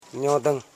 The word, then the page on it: nyodung: nyo.dung